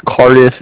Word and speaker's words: Cardiff: It's Cardiff. It's spelled C-A-R-D-I-F-F. It's like car indifferent.